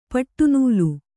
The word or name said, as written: ಪಡನೆಲ